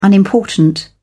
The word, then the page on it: unimportant: British English